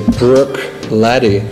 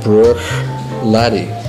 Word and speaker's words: Bruichladdich: That's interesting, because we were doing the interview in English, and if you would say it in the English way, you would say Bruichladdich, because the British, the Americans, and also the French, they don't have a sound in their language, so they have a hard time pronouncing it the right way. It's actually... Bruichladdich. So both are good. The most important thing is that you don't pronounce the last two letters, the C and the H.